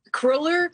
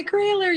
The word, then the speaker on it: Kohler: So Kohler, people are going to be calling in, oh, you pronounce it by Kohler, you pronounce it Kohler. Just for everybody out there, people have decided that it's either pronounced one way or the other, and we except both of them.